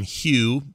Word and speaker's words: Hue: P-I-N-E. And in it, you play this kid named Hugh, but it's spelled like the light. Like Hugh the light. – H-U-E.